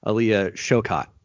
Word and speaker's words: Aaliyah Shawkat: Against all odds, I found out that her name is pronounced Aaliyah Shawkat.